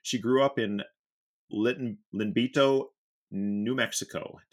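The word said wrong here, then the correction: Iyanbito: No, it's actually pronounced a Yumbato, and it's a small community on the Navajo reservation. – So, Yumbato? – Yumbato, yes.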